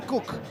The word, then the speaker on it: Cook: Se pronuncia igual que decir cocina en inglés, Erwin Cook.